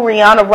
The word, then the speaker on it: Rihanna: Going out to my boo, Rihanna Robin Fenty, which is actually the proper pronunciation, but she don't really care how you say it.